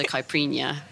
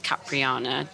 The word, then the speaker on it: caipirinha: So it's not pronounced Capriana, just so anyone knows that's very different, yes.